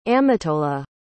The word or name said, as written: amitola